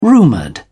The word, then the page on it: rumoured: British English